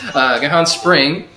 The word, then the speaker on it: Gihon Spring: They left the temple and they went to the, how do you say that, Gihon Spring? – Close enough. – Gihon Spring, okay,